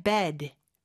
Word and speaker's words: bed: 그 다음에 bed, bed. 에 발음보다는 좀 더 약간 눌러주는 느낌의 bed로 해주셔야 돼요. 이게 중요한 게 bad, 나쁜의 bad하고 발음이 좀 헷갈릴 수가 있어요.